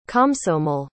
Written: komsomol

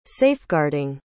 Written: safeguarding

